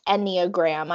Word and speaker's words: Enneagram: So it is pronounced Enneagram. I've heard a lot of people say it, lots of different ways. It's a lot easier to say than it's spelled, I think.